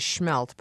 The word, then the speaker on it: smelt: And That's the proper Wisconsin pronunciation is schmelt, but I guess I said smelt.